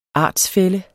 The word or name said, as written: artsfælle